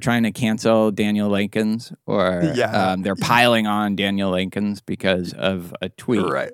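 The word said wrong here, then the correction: Lakens: So I've learned that I'm pronouncing it wrong. It's Lackins.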